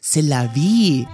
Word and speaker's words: C'est la vie: final destination แล้วหนึ่งเอาล่ะหมดคำศัพท์ภาษาอังกฤษที่มาจากรากศัพท์ VIP ในวันนี้แล้วนะแต่เรื่องราวของเรายังไม่จบแค่นี้ครับแมนขอปิดโค้งสุดท้ายด้วยสำนวนที่เพื่อนๆอาจจะได้เจอในชีวิตการใช้ภาษาอังกฤษครับสำนวนแรกครับ C'est la vie ถ้าแปลสำนวนนี้ก็คือ That's life หรือเป็นไทยก็คือชีวิตมันก็เป็นแบบนี้ล่ะ C'est la vie ครับสำนวนนี้ออกเสียงแปลกๆแบบนี้แน่นอนไม่ใช่ภาษาอังกฤษแน่นอนแต่เป็นการยืม expressions มาจากภาษาฝรั่งเศสครับแล้วก็นิยมเอามาใช้ในภาษาอังกฤษเหมือนกันนะแมนก็ได้มีโอกาสไปเจออยู่เรื่อยๆอยู่เนืองๆนะคะ C'est la vie